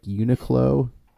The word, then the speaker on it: Uniqlo: I don't have a strong, I will say, like Uniqlo, I buy some stuff from because it's cheapish.